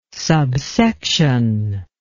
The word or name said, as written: subsection